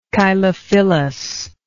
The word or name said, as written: chylophyllously